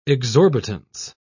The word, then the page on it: exorbitance: British English